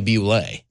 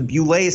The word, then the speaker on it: Boule: Well, if you look at the tattoo on LeBron James's chest, there is a lot of speculation that LeBron comes from the secret society known as the Beulah Society. It's spelled B-O-U-L-E. Sometimes you'll hear it called the Buell Society.